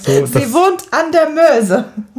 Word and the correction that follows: Meuse: Das ist wieder mein schlechtes Französisch und die Betonung. Ne? – Ja, genau. Ich glaube, es heißt wirklich La Meuse.